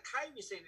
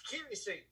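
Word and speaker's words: Kairi Sane: Her name is not Kairi Zane, it's Kiri Zane.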